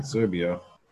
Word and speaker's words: Serbia: And then you want, then I did, I tried Siberia. – Then you Serbia. Yep. Sorry, Serbia. I should say that right. Think of Russia, Siberia.